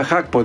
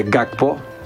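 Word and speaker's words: Gakpo: Χάκπο λέγεται, προφέρεται, δεν προφέρεται γκάκπο, κύριε Σιδέρη, αελάρα.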